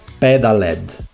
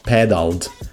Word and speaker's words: Pedaled: Is Pedaled, not pedaled, not pedaled, it's Pedaled. Yeah, I spell it correctly, finally, and yeah, now I feel a bit better.